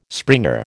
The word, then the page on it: springer: American English